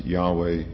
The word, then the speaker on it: Yahweh: And even in their writings, they recognize Yahweh as being a more correct pronunciation.